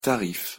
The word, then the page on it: tarif: French